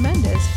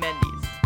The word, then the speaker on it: Mendes: Thank you to Sean Mendes, Scott Harris, Nate Mercero, and Teddy Geiger for writing it, and to Sean Mendes for singing it. – You mean Mendes? – Yes. Whatever. Anyway. I was trying to pronounce his name correctly. – No, you did.